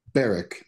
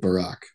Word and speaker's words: Barak: It's Ehud Barak, not Ehud Barak.